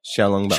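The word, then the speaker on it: xiaolongbao: I made Zhang Long Bao from scratch. No? – No. – How do you say that? Zhao Long Bao? – No. – Zhao? – Xiao. – Xiao Long Bao.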